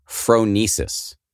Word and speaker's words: phronesis: The next one is, and please correct me if I'm mispronouncing this, phronesis. – Good.